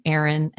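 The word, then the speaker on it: Aryn: It's A-R-Y-N, Aaron, and then Michelle is normal.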